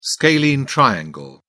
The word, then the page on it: scalene triangle: British English